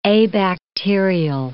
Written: abacterial

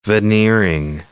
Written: veneering

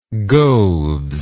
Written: goaves

